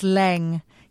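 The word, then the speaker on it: Laing: Wouldn't it be good to just cut him out and say, I'm afraid, you know, this podcast does not recognise Jamie Lang as being in season 18. – I love that you're antoning him. You're still calling him Lang. Apparently it's Leng. You pronounce it Leng.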